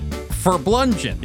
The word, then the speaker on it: Farblunget: Let's see you pronounce it. I'll help you. – All right. Here we go. Take a deep breath. Farblunget. – Farblunget. – Farblunget. – Yep.